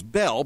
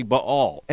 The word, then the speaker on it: Baal: Many people have heard his name pronounced as Bel, but it's actually a diphthong. It is actually Baal.